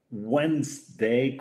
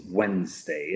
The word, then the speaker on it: Wednesday: Y otra gente, mucha gente dice Wednesday con una D, pero la D tampoco se pronuncia, ¿eh? Es Wednesday. Es más como si hubiera una Z al final, ¿eh?